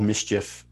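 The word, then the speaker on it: MSCHF: It's called Mischief. MS. CHF, mischief.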